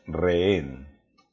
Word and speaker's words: rehén: Y por lo tanto esta palabra se dice rehén. Bien, recordar, la H con nosotros no suena, rehén.